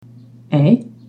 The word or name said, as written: e trema